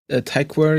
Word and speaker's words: Tykwer: Und dann 2012 Cloud Atlas, Drehbuch zusammen mit Tom Twyker. Tykwer, ich glaube, hatte das.